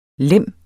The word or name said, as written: LEMMENE